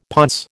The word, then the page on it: ponce: American English